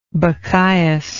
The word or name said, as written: bacchius